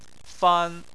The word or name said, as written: fan1